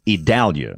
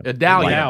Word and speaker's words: Idalia: Yeah, you know, we've decided that the pronunciation we believe is correct. It's Idalia. – Idalia. – Like Dallas. Like Dallas.